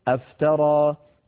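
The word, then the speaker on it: أفترى: قوله تعالى افترى على الله كذبا، اصلها افترى، فدخلت الهمزة على فعل افترى فحذفت همزة الوصل كما ترون خطا ولفظا فيصير النطق افترى.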